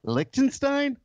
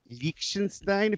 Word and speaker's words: Liechtenstein: We went to Liechtenstein? – Yeah. – Liechtenstein, if you want to say it properly, I think.